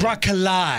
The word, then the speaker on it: broccoli: Broccoli. – I don't even use the second O when I say broccoli. It's just broccoli. – Broccoli. – But broccoli. – A two-syllable word. – Yeah. – Broccoli. – Yeah. – B-R-O-C-K-L-E-E.